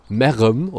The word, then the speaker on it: Merheim: Ähm, dann der, der Name Meerheim. Also erstmal wird es auf Kölsch wieder so richtig geil ausgesprochen. Merrem, oder, mhm. also es wird M-E-R, R-E-M geschrieben dann.